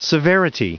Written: severity

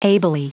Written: ably

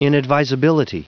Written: inadvisability

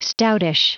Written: stoutish

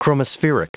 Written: chromospheric

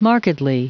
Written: markedly

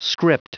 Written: script

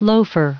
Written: loafer

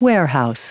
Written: warehouse

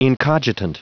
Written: incogitant

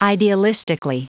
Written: idealistically

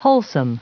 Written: wholesome

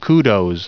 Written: kudos

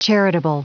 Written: charitable

